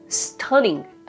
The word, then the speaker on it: stunning: 音節は 2 つで stunning です。アクセントは u につくので stunning となります。